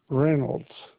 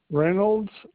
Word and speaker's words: Reynolds: Elwood Reynolds. – Okay, and can you spell that for me? – Elwood, ELWOOD, Reynolds, REYNOLDS.